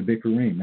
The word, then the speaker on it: Bikurim: Of the Bikurim, that's how you say it in Hebrew.